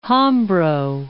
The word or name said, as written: Hambro